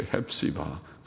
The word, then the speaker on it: 헵시바: 헵시바 그리고 비울라, 그런 말을 여기 히브리어 발음대로 그렇게 해서 헵시바, 헵시바 온 비울라.